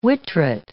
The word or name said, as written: whittret